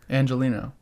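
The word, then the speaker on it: Angeleno: Angelino.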